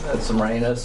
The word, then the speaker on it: Semiramis: Semiramis.